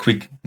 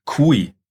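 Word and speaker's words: QOI: Oder äh, Quick, nicht Quick. Äh, Kui, so spricht man es aus.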